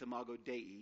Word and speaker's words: Imago Dei: Technically, it's Imago Dei is how you say it in Latin.